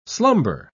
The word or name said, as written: slumber